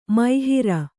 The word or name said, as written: ಮೋಹರಿ